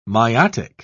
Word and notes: miotic: British English

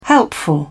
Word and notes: helpful: British English

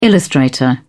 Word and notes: illustrator: British English

